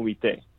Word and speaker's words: bistec: Pan con bistec. So it's spelled like the C in bistec is not, you don't enunciate it, kind of like the L in salmon. Mm-hmm.